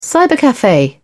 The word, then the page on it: cybercafe: British English